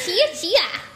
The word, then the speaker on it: Chiacchia: So C-H-I-A-C-C-H-I-A. Chia Chia.